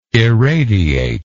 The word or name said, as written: eradiate